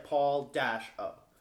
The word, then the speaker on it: Paolo: In his Twitter, it says like Paul-O.